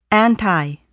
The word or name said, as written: anti